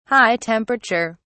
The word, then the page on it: high temperature: British English